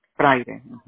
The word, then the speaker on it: Frayre: Now, for the account purpose, your first name is Marco, M-A-R-C-O. What's your last name? – Friday, F-R-A-Y-R-E. – F-R-A-Y-R-E? – Friday, F-R-A-Y-R-E. – Y-R-E, okay. Friday, is that how you pronounce it? – Friday.